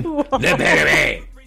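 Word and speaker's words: Le Pétomane: French pronunciation, Le Petitmain.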